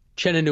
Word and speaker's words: Uchenna: Chena Nuosa. – Nuosa. – Okay. It, it, it's it's spelled like Uchenna, but the U is silent.